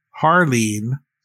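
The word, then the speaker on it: Jarlín: So the first one that I want to talk about is Harleen Garcia is the way it's pronounced.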